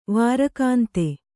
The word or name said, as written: ವಾರಕಾಂತೆ